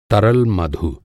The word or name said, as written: मधु